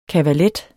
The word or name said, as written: kavalet